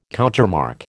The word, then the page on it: countermarch: American English